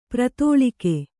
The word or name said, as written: ಪ್ರತೋಳಿಕೆ